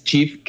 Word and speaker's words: Cheaf: Bueno, primero, tal como mencionabas, Chif, que se escribe CHEAF, C-H-E-A-F, es una aplicación móvil que tiene como misión, ¿no?, como objetivo, evitar el desperdicio de alimentos, ¿no?